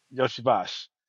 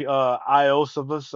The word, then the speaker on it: Iosivas: Yoshivash. – Yeah. If you look at his name, it looks like Iosivas or something like that, but it's Yoshivash.